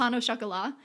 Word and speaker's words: pain au chocolat: Oh, pain au chocolat.